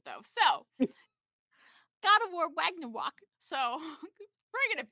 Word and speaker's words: Ragnarok: Ragnarok. – Ragnarok.